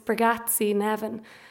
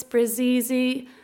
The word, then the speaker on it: Bregatzi Nevin: It's Bragazzi Nevin. And so for my teaching career, that has led to some really interesting pronunciations. I've been Miss Brazizi, Miss Spaghetti, Miss Bragazzi Heaven, Miss Bagatizzi.